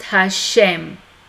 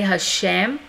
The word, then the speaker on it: Hashem: And in that case, it's accompanied by an apostrophe and pronounced Hashem, the name. So just FYI that Jewish people never pronounce Yehovah. Instead, they always say Hashem, and they write just the letter hey with apostrophe.